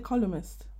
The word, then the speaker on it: columnist: I don't pronounce the N, you know. – Oh. – I say columnist.